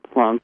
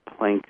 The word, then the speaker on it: Planck: Max Planck, or Planck, some people, if they Englishize the last name, will be pronounced Planck, came up with.